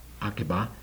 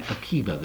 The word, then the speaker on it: Akiva: And mind you, even the chief rabbi of Jerusalem, Rabbi Akiva, or sometimes pronounced Akiba, but Rabbi Akiva,